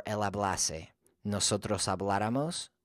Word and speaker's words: habláramos: Nosotros habláramos. And if you think about that word habláramos, it's stressed on the third to the last syllable, habláramos.